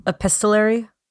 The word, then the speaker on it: epistolary: Like epistolary? – Epistolary? – Epistolary, yes. I added an extra syllable there.